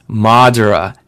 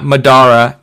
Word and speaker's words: Madara: It's Naruto, not Naruto. It's Madara, not Madara, and it's Uchiha, not Uchiha. So, good job.